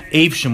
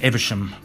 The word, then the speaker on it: Evesham: And we have Keith, Paul and Guy here, and we are in Evesham, which I thought was pronounced Evesham, but it's pronounced Evesham.